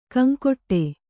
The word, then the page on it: ಗೊಂಗಟೆ: Kannada